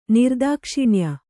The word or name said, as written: ನಿರ್ದಾಕ್ಷಿಣ್ಯ